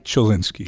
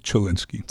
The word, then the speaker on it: Chelinski: I was saying for the long time Chelinski, but it's Chelinski, right? – Chelinski. – Chelinski. So the ch is pronounced. – It's the Americanized version. I understand in Poland the CH would actually be a H sound, like a Chelinski. – Chelinski. – Yeah, yeah. – Okay. – But we've always pronounced it Chelinski.